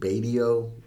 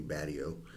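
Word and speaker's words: Batio: That Michelangelo Badio, which I think he calls it Badio, but there's no long A in Italian, so it's actually Badio.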